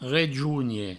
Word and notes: rejonher: rejougne

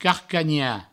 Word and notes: carcanhar: provençal; carcagna

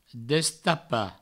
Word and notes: destapar: destapa; Provençal

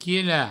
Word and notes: quilar: provençal; quila

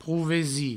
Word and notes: provesir: prouvesi; provençal

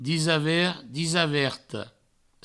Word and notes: disavert, disaverta: disavert, disaverto; Provençal

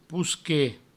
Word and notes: posquer: pousque; provençal

